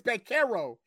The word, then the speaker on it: Banchero: Oh, it's with a K. They Banchero. It's Banchero. – Oh, it's Banchero? I've been butchering this nigga name since Duke, fam. – You got the ch on it. It is actually a k.